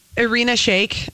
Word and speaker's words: Irina Shayk: Irina Shayk.